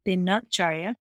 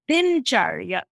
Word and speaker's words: Dinacharya: So in Sanskrit, it's pronounced Dina, because null has to get released with a vowel, Dinacharya. In Hindi, it's pronounced Dincharya or Dincharja.